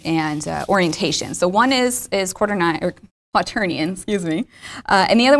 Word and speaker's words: quaternion: I totally called it quaternion. Quaternion. Always get made fun of for that. I don't know why. I look at it and I think it should be pronounced differently than it really is.